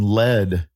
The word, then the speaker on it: lead: There's a reason lead rhymes with dead.